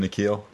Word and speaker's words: N'Keal: N'Keal.